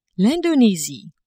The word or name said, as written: Indonesie